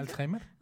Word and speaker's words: Alzheimer: Alzheimer.